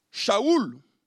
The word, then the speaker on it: Shaoul: Et d'ailleurs, son nom, Shaoul, si on veut prendre la prononciation hébraïque, évoque le nom du premier roi d'Israël, Saül.